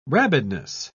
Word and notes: rabidness: British English

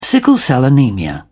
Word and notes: sickle-cell anaemia: British English

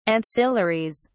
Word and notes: ancillaries: British English

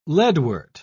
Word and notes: leadwort: British English

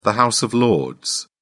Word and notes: House of Lords: British English

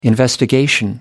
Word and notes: investigation: American English

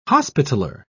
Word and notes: hospitaller: British English

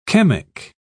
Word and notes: chemic: British English

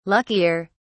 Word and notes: luckier: British English

